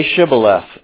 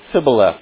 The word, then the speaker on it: Shibboleth: You come and they say Shibboleth, and if he said Sibboleth because he doesn't know how to make that sh sound, you're lying that you're from Israel, from the rest of Israel.